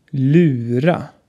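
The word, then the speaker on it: lura: Das Wort Lyra bedeutet verarschen oder austricksen und wird mit einem Ü ausgesprochen. Lyra.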